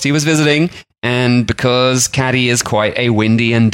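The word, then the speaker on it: Cádiz: He made a visit down to Cadiz in the south of Spain, spelt Cadiz for us English people who can't pronounce it properly, but I was informed reliably that it is Cadiz, which I was informed reliably when I was on a walking tour there.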